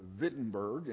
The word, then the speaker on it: Wittenberg: But in Germany, they pronounce a W like a V, and so it is literally Wittenberg in Germany.